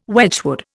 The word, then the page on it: Wedgwood: Indian